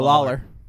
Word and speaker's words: Lawler: Tom Lawyer, not Tom Lawler. – Is that it? I thought when you pronounced it earlier, you said Lawyer. – No, Lawler.